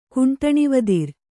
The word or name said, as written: ಕುಂಟಣಿವದಿರ್